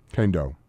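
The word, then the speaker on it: Kaindoh: Okay, Joshua Kendall. Is that how you say it? – Kendo.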